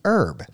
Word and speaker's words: herb: it was spelled E-R-B, so it would be herb. But then in the 15th century, the spelling was altered. They added an H to the front, so now we spell it H-E-R-B. Now, if we were to travel to Britain and part of the Commonwealth nations, our English-speaking cousins would pronounce, usually, the H, the herb.